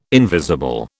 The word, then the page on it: invisible: British English